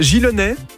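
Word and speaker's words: Gillonnay: Chantal, vous habitez Gilonnais, on le prononce comme ça, magnifique, c'est à côté de la Côte-Saint-André. – Voilà, oui.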